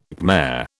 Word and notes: Khmer: British English

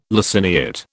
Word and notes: laciniate: British English

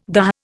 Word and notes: dah: Indian

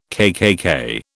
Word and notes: KKK: British English

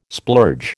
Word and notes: splurge: American English

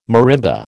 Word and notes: marimba: American English

